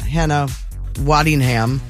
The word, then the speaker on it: Hannah Waddingham: There's a whole scene in this show, that Ride or Die show with Octavia Spencer and Hannah Waddingham.